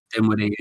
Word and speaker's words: Dinwiddie: Before the last game, because Spencer Dinwiddie, is that how you say it? – Yeah, Dinwiddie.